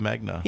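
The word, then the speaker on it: Megna: Jacob Magna. – Yeah, who is that? – Rhymes with smegma.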